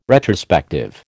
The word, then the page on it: retrospective: American English